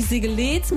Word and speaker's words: Segeletz: Genauso wie Segeletz mit langem E oder Bückwitz mit langem Ü.